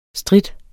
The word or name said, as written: strit